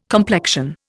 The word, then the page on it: complexion: Indian